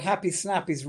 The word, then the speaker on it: Happy Snappies: So the first thing you need to know is that happy snappies rhymes with nappies.